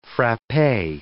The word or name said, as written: frappe